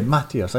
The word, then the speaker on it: Mateus: It just sounds completely different when you put the emphasis on a different part of the word. So, Mateus, I got to put the emphasis at the start. – Definitely. – Anyway. – You're putting emphasis on the wrong syllables.